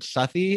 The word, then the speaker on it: Southey: The first one is Wat Tyler, which is by, I'm not sure if I'm pronouncing the name correctly, but Robert Southey, is that correct? – Yeah.